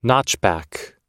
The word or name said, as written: notchback